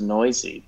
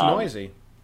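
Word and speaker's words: Neuse: Baseball Reference does say it's pronounced Noisy, but we've seen some of the perils of that before. – I think it's Noisy, which is fun.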